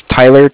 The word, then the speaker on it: Tyler: My name is Tyler, T-Y-L-E-R. And I'm calling with Cardiff. It's spelled C-A-R-D-I-F-F as in Frank Frank.